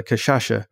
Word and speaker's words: cachaça: but Kashasha. I'm going to try. – Yeah, the cachaça, which is their sugar based alcohol, and mix it up.